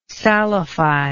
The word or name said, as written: salify